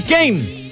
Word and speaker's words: Kane: Harry Kane. – Ya. – Una sílaba, es una sílaba, sí.